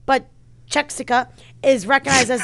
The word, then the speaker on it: Czechia: Czech Republic and Czechia, I didn't say it right, whatever. They refer to the same country. – Yes. – The Czech Republic is the official name. Wow, what is it? Czechia? – Czechia.